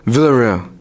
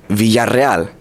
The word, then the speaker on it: Villarreal: So yeah, there's two L's, and the two L's is a ya sound, and the two R's is a rrr sound. So it's Villarreal, right? Ooh, that's my last name. So don't say Villarreal, because that's not the right way to say it.